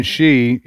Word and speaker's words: Xi: We keep saying Xi, it's X-I. That's the way you pronounce it.